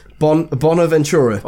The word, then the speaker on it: Bonaventura: His name was, I'm gonna butcher this. Bonaventura. – Oh, nailed it.